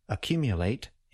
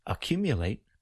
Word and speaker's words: accumulate: In the verb accumulate, we often put the main or primary stress after the beginning vowel sound. We have secondary stress toward the end of the word. I will clap where we generally place the main stress.